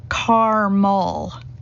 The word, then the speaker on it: caramel: Said with two syllables here, caramel.